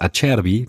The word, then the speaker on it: Acerbi: Also das Acerbi, das war aber einwandfrei ausgesprochen.